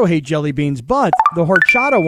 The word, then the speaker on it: horchata: Horchata. It's silent is H is silent.